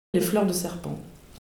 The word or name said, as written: fleur de serpent